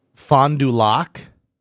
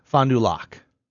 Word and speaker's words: Fond du Lac: Fond du Lac. I'm pretty sure I'm saying that right because it's a French.